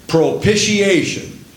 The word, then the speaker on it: propitiation: That word propitiation, pronounced pro-pish, like fish, pish, propitiation, is number 2435, and it means an expiatory thing.